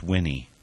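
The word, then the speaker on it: Winne: I know of four others: Alice Hertz, Roger Allen Laporte, Florence Beaumont, and George, it's spelled W-I-N-N-E, I think that's pronounced Winnie, all of them who self-immolated in protest of the Vietnam War.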